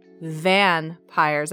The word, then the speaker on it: Vanpires: I said that correctly Vanpires. That's V A N P I R E S.